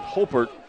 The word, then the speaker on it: Haupert: That was kind of the illustration right there by quarterback Luke Hopert, H-A-U-P-E-R-T, but pronounces it Hopert,